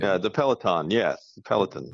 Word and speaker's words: Peloton: Peloton. – I like that. I like that. It sounds like Pelican.